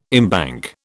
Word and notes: embank: British English

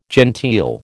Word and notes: genteel: American English

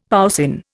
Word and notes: poussin: Indian

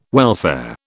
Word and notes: welfare: British English

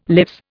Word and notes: lives: Indian

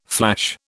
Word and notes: flash: British English

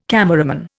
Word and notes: cameraman: Indian

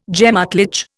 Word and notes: gemÁtlich: Indian

